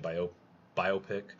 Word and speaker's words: biopic: Uh, next up here, we got two biopics uh, or biopics. Um, I, I, prefer, I, I, prefer, the term bi, bi, the pronunciation bio biopic.